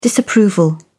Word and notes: disapproval: British English

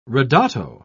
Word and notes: ridotto: British English

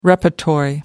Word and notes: repertory: American English

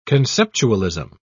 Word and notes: conceptualism: British English

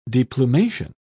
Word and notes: deplumation: British English

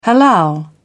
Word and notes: halal: British English

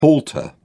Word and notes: halter: British English

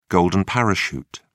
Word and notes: golden parachute: British English

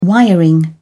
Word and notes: wiring: British English

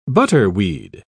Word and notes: butterweed: British English

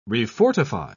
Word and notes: refortify: British English

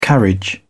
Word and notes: carriage: British English

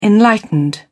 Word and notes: enlightened: British English

